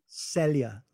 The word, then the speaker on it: Celia: Celia, like you're saying celery. Celia.